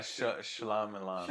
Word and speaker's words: Shyamalan: Yeah, Shyamalan.